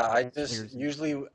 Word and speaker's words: Swierczynski: This writer, I'm going to mispronounce his name probably. Dwayne Swierczynski? Does that sound right? – Yeah, Swierczynski.